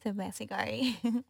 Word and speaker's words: Sebasigari: Sebasigari. – Which is spelled Jennifer, J-E-N-N-I-F-E-R.